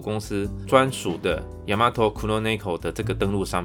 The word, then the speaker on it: ヤマトクロネコ: 那黑猫商标名称念作 Yamato Kuroneko，所以全新的黑猫商标将从下个月四月一号开始使用。